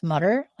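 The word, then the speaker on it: Mütter: And I'm actually reading a book on, I think it's pronounced Mutter, like the Mutter Museum, but it's the Dr. Mutter.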